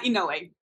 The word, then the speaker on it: Inouye: Dana Inouye.